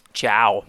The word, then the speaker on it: Ciao: How do you pronounce his first name? C-I-A-O. – Jason. Who? – Chow.